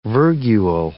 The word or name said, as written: virgule